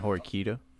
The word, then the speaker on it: Horikita: Horihika? Is that right? – Oh, did I say Susan? – You mean Horikita? – Horikita, thank you.